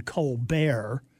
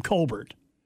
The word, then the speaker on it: Colbert: I know, he likes to use the affectation Stephen Colbert, even though the other members of his family pronounce their last name Colbert, was using the funeral and the lying in repose at the nation's capital of George H.W.